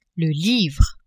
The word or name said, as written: livre